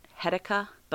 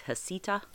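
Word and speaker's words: Heceta: Some people have pronounced it Hedica, but the correct pronunciation that I found was Hasita.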